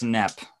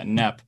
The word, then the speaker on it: KNEP: It's it's Nep. And that's how it's pronounced. Yeah. Uh, Nep. Um K N E P for anyone that didn't know that.